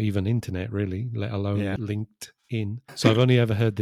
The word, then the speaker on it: LinkedIn: LinkedIn. Yeah, there's a strong T there.